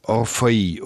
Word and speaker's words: Orfeuil: Und ich habe gefunden, ich buchstabiere mal, ich habe folgende Variante gefunden, O-R-F-E-U-I-L. Ich würde sagen Orphei oder etwa so, ja?